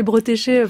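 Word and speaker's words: Bretécher: En plus, quand on lit du Bretéché aujourd'hui, alors j'ai du mal à le prononcer Bretéché parce que on a dit avant en antenne que c'était comme ça. Moi j'ai toujours dit Bretéché, mais du coup j'ai un peu de mal.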